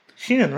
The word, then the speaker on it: Shein: But in reality, you got it off of, like, Shein. You don't want to tell them because you don't want them to, quote-unquote, jack your style. Is that how you not pronounce that? – Shein? – Shein, right? – Shein.